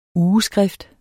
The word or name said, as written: UGESKRIFT